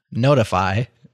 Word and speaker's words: ntfy: NTFY, pronounced Notify.